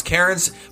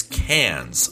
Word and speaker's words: Cairns: I love the way you pronounce Cairns, but if you want to know how you really pronounce it, it's Cairns.